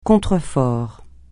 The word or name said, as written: contrefort